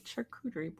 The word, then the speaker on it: charcuterie: They're not even saying it right, aren't they? – No, yes, yeah, not. – It's charcuterie board. Charcuterie. Right? Yeah, I said it wrong.